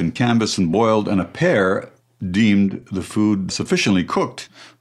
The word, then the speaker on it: Appert: And of all people, a confectioner and chef in Paris named Nicolas Appert, A-P-P-E-R-T. You're better at the French pronunciation. – That would be correct. – Would yeah, Appert.